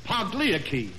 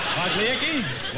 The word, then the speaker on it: Pagliacci: I'm going to do Pagliacci. – Pagliacci? – Well, Pagliacci, yes.